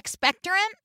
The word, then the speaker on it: expectorant: Is that right? Is that how you say it? – Expectorant? – Thank you. – Expectorant. – I put the wrong emphasis on the wrong syllable.